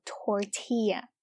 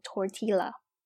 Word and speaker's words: tortilla: 那这个玉米饼呢，我们叫做 tortilla。那注意呢，因为这是西班牙语，所以呢我们在发音的时候不能叫做 tortilla。那这个玉米饼啊，就是我们平时比如说像在肯德基里面点的墨西哥卷啊，用的那个饼就叫做玉米饼。那再说回来，那这个玉米片呢，就叫做 tortilla chips。